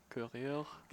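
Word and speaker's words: Courrèges: Can you just look up AC Corriger? Corriger is spelled in a French manner, so C-O-U-R-R-I-G-E-S. – Oh, like running? – Yeah. – Courier? – Yeah, there it is.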